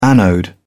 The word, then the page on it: anode: British English